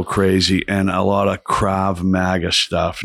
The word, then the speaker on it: Krav Maga: Krav Maga. Yeah. Yes. Krav Maga.